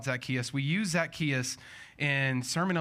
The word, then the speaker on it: Zacchaeus: Zacchaeus, yeah.